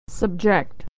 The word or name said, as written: to subject